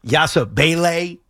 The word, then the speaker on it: Yabusele: By the way, I've gone back and forth on the correct pronunciation of this. Yabusele is what I've heard.